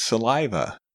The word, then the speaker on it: saliva: Saliva has a short A, long I, and short A sound.